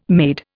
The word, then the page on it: made: Indian